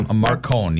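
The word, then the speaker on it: Marc Cohn: Well, yeah, Mark Cohn, yeah.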